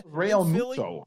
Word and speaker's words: Realmuto: Realmuto.